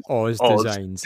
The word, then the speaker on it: OZDZYNE: Yeah, it's spelled OZDZYNE. – S. Literally says Oz Designs.